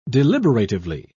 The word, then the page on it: deliberatively: British English